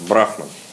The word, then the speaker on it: брахман: Да, исправить о том, что безличное начало, брахман, ударение ставится на «а». Последнее. На самом деле, то есть я как бы опирался на Википедию, где оно ставится на последний слог. В тех источниках, по которым я учил, как бы вообще ударений нету. Но тут посоветовался с санскритологами, они сказали, нет, на первый слог ставится брахман.